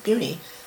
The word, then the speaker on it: Buena Vista: Bueno, it's a Spanish word, and I suppose it's actually pronounced Buni. A lot of them call it Buni. – Right. – Buena Vista. – Okay. – I think it's really said Buena. – Buena? – Buena Vista, or something like that.